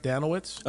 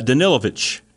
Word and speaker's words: Danilovich: Uh, am I saying this right, Danowitz? – Uh, Danilovich is how he Danilovich. says it with the V sound.